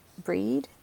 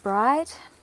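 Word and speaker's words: Brigid: I was going to say Breed because that's, or is it Bride? Are the two Irish pronunciations, Irish Celtic pronunciations, but I'll call her Bridget.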